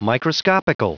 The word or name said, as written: microscopical